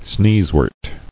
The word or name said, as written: Sneezewort